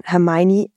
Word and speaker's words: Hermione: Im vierten Band schmuggelt die Autorin ein, wie man Hermione richtig aussprechen soll. Hermione.